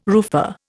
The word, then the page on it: roofer: Indian